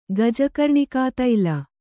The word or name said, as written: ಗಜಕರ್ಣಿಕಾತೈಲ